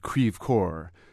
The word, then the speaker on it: Creve Coeur: Well, I grew up in the western suburbs of St. Louis, at first a suburb called Creve Coeur, which is the funny Midwestern pronunciation of the French for broken heart.